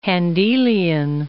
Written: Handelian

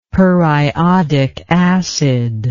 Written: periodic acid

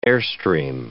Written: airstream

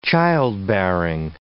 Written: childbearing

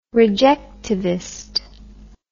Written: rejectivist

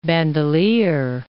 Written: Bandelier National Monument

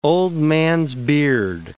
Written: old-man's beard

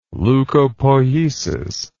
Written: leucopoiesis

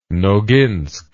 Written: noginsk

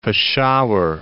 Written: Peshawar

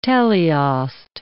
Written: teleost

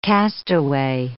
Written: castaway

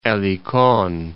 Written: Elikon

